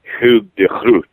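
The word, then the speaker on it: Hugo de Groot: But Grotius was known in his own time by his Dutch name, which is the rather more gutturally pronounced Hugh de Groot.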